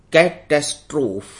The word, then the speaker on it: catastrophe: Basically कैटास्फ्रो होता क्या है, इसकी स्पेलिंग है CATASTROPHE, कैटेस्ट्रोफ।